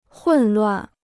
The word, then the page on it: 混乱: hùn luàn